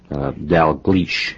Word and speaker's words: Dalgleish: Now, most of us refer to the commander as Dalgleish. – That's that correct? – Absolutely correct. – Scottish? – It is Scottish.